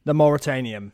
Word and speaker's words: The Mauritanian: The Mauritanian.